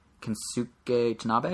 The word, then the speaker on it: Kensuke Tanabe: In the same interview with IGN, the producer of Donkey Kong and Metroid Prime, a man named Kensuke Tanabe, I think I said it right? – Yep. – I know his last name's Tanabe for sure.